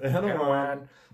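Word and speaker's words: Erdoğan: Hat sie Erdogan gesagt? Erdogan? Erdogan. Erdogan. Erdogan. – Erdogan. – Ja, also das, das war richtig.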